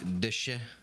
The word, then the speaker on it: déchet: I looked up the pronunciation of this in French before the show, and I'm gonna butcher it. It's like déchet.